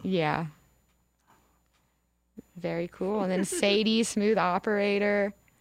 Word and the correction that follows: Sade: Sade, but yes. – Oh, it's Sade? – It's pronounced Sade, yes.